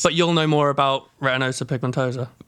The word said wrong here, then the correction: retinitis pigmentosa: Retinitis pigmentosa. – That'll do. – See, said it wrong straight away. – I thought you were spitting bars. – I said it wrong anyway. Retinitis pigmentosa.